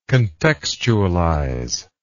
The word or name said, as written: contextualization